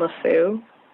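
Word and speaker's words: Lafeu: When we heard it in play, they said Parolles and Lasso. That's how, when I looked it up, it said like the British normally pronounce it.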